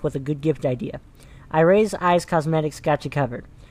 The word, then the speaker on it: Irie: Iray's Eyes Cosmetics. That's spelled I-R-I-E, Eyes Cosmetics.